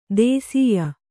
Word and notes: ದೇಸೀಯ: dēsīya

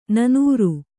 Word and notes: ನನೂರು: kannada

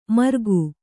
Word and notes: ಮರ್ಗು: kannada

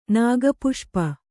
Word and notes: ನಾಗಪುಷ್ಪ: nāga puṣpa

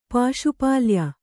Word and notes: ಪಾಶುಪಾಲ್ಯ: Kannada

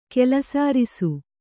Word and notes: ಕೆಲಸಾರಿಸು: Kannada

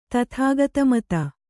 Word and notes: ತಥಾಗತಮತ: tathāgata mata

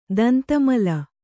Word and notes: ದಂತಮಲ: Kannada

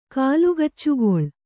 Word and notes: ಕಾಲುಗಚ್ಚುಗೊಳ್: Kannada; kālugaccugoḷ

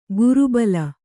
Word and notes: ಗುರುಬಲ: guru bala

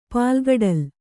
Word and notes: ಪಾಲ್ಗಡಲು: kannada

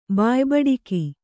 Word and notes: ಬಾಯ್ಬಡಿಕಿ: bāybaḍiki

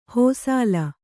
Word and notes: ಹೋಸಾಲ: hōsāla

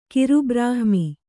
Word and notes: ಕಿರುಬ್ರಾಹ್ಮಿ: Kannada

